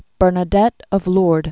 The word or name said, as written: Saint Bernadette of Lourdes